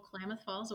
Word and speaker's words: Klamath Falls: You grew up in a place called, and if I mispronounce it, you're going to help me, Klamath Falls, Oregon. Is that correct? – Yeah. Yep. Good old Klamath Falls, Oregon.